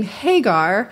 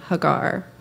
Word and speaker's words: Hagar: Most English pronunciations pronounce her name Hagar, but for the sake of honoring this woman, I will attempt to use the Hebrew pronunciation of her name, Hagar.